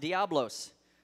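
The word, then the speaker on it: diabolos: False accusers, that word means, is actually pronounced diablos.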